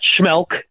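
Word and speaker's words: Schmeelk: I'm on Twitter at Schmelk. Now I'll have to spell it for you because my name's ridiculous. S-C-H-M-E-E, two E's, that's the trick, L-K.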